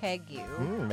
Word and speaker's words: Pegu: Pegu. – Maybe later. – Club cocktail. That's how I'm saying it. It's spelled P-E-G-U.